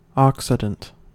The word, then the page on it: occident: American English